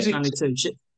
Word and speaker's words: Stanley Tucci: Stanley Tucci.